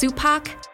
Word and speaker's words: Supac: Remember, it's Supac rhymes with Tupac Keenitz.